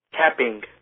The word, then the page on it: capping: American English